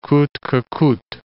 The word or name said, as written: coute que coute